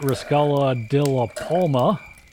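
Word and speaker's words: Resaca de la Palma: Taylor wins again at Riscala de la Palma. Did I pronounce that wrong? – Perfect.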